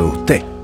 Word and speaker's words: usted: La zamba de usted. Usted con acento en la E.